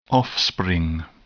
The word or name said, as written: offspring